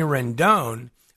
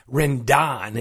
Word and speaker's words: Rendon: Now, Anthony Rendon, or if you listen to Tom Boswell the other night, Rendon, it's like that commercial on TV, it's pronounced Gordon.